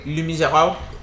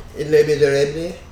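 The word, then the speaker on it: Les Misérables: Les Miserables. How do you pronounce that? What's the correct way? – Les Miserables. – Les Miserables.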